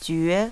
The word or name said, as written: jue